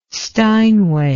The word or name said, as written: steinway